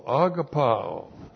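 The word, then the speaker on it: Agapao (ἀγαπάω): God the Father is the subject of the verb here, and the verb is agapao. A-G-A-P-A long O. Agapao.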